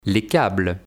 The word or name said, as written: Les câbles